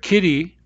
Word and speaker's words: kitty: Now consider the word kitty. We write kitty with a double T, but actually it's not pronounced t. It's not pronounced like the t in tiny. It's pronounced more like r, kitty, r, r.